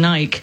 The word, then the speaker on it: Nike: Like, for example, Nike, which if we're going to give the British pronunciation, is Nike. that's what they do in their commercials, by the way.